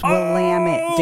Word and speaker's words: Willamette: I will come on this show and I will debate this right now that if you are in Oregon, it is pronounced Willamette. It's Willamette, damn it.